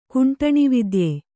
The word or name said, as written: ಕುಂಟಣಿವಿದ್ಯೆ